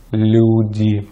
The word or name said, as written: люди